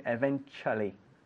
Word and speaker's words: eventually: Be careful with the pronunciation because we write it eventualmente, with con dos L's, eventually, but we pronounce it eventually.